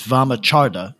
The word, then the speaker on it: Vamachara: It's a Vedic word. So it's Vamacharda is actually closer to how you would say it, but I doubt we're going to get anybody to say it that way.